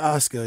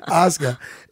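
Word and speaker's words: Oskar: Yeah, Oscar. It's just spelt with a K. That's why I thought. Oscar.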